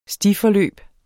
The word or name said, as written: stiforløb